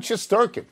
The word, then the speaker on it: Shesterkin: We'll see with Shesterkin.